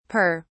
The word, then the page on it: pere: British English